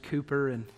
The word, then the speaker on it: Cowper: So this would have been one of his, probably written when he was pastoring in Olney, O-L-N-E-Y, in England, maybe alongside probably at that time, William Cowper, it's spelled Cowper, C-O-W-P-E-R, but it would be pronounced Cooper, and they wrote many hymns together.